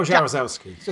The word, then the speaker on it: Jaruzelski: Uncontested, because I can't say his name, the leader, Jaruzelski. – General Jaruzelski.